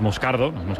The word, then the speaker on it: Moscardo: Como bien ha pronunciado Unai, es Moscardo, brasileño.